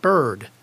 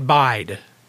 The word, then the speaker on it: Byrd: Her name is Levette Bird, B Y R D. But it said Levette Bide. It didn't say the R for some reason. So I just changed the spelling from a Y to an I. So it would be spelled like bird and then it would be pronounced like bird.